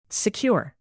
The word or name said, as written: secure